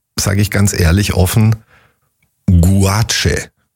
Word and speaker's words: Gouache: G-U-A-C-H-E. – G-O-U-A-C-H-E. – Guache. – Guache. – Guache. – So spricht man es aus.